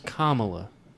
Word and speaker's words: Kamala: I believe it's pronounced Kamala. – Do you know? – Kamalama ding dong. – bombed. Kamala. – Kamala. Absolutely bombed. – It's like, what is it like? Oh, Kamala, like, kumbaya.